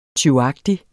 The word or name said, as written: tyvagtig